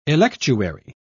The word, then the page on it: electuary: British English